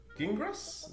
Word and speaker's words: Gingras: And the announcement was by Richard Gingras? Is that right? – That's exactly how you say it.